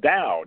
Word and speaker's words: Dao: It's pronounced Dao. Daoism, it's the same character. Dao is the Chinese pronunciation. Do is the Japanese pronunciation. Chinese is Dao, Japanese is Do.